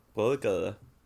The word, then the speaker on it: Bredgade: Om, du, om jag ska följa din regel utan att, utan att uttala det, då skulle det bli Brögger Bra! Brögger Brögger!